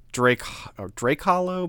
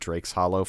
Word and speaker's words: Drake Hollow: I think it's Drake Hollow, but I said it Drake's Hollow 400 times.